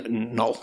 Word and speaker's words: Noel: Noel, jeez, I'm using the proper pronunciation.